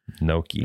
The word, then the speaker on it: gnocchi: It's spelled G-N-O-C. – Gnocchi. – Gnocchi. – Yeah. Mm-hmm. Gnocchi. I didn't know how to pronounce it.